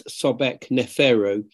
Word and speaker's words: Sobekneferu: Well, I think if you say it slowly, it's Sobek Neferu, which means the beauties of the god Sobek.